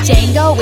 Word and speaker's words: Django: Django with the silent D, bounty hunters all want me.